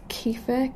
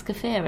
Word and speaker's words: kefir: So yeah, she drinks kefir. I always say it wrong. – I really want to start drinking kefir, but it's kefir.